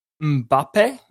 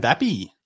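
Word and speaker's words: Mbappé: Two votes is Kylian Mbappe. That's how you say it. – Mbappe.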